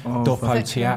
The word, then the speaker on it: Dopo Teatro: that's my Italian pronunciation, Dopo Teatro, what's that mean? – Which means after theatre.